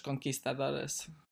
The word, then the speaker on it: conquistadores: the way, like, I think I've always heard it as conquistadors, or I guess in Spanish, conquistadores. Yeah. – Sure. Maybe, maybe I've just, maybe just, I've got the wrong just, pronunciation there. No, you don't have wrong. It's just different, and I thought it was interesting to point out.